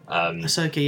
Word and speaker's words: Ahsoka: It was slightly weird seeing her as Ahsoka, or Ahsoka, I think I'm saying that right. Whatever. – Ahsoka, yeah, I reckon so.